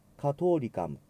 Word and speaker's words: catholicam: はい、カトリカム THO H を発音しませんのでカートという発音になります。カトリカム et apostolicam ecclesiam confiteor unum baptisma in remissionem peccatorum et expecto resurrectionem mortuorum et vitam venturi seculi amen。